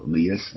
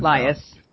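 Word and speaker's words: Liias: And you have two progressives in that race, Marco Lias and Lias, yeah. – Lias, sorry. I never know how to pronounce it.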